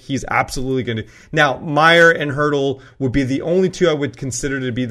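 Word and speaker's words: Meier: Is it Meyer or Meyer? Everybody knows I have issues. Meyer. – Meyer, thank you. – Timo Meyer.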